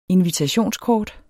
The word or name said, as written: invitationskort